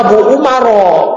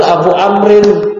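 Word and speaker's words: Abu Amrin (أبو عمرو): Kalau enggak ada wawnya antum bacanya Abu Umaro. Padahal Abu Amrin.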